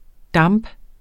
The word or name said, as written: DAMPENE